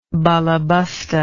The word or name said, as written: baleboste